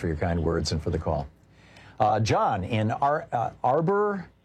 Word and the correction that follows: Auburn: California. Am I saying it right? – Auburn.